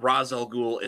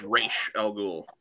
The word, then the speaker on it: Ra's al Ghul: Because it's not Ra's al Ghul, it's Ra's al Ghul.